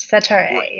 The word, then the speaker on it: Setareh: Setareh.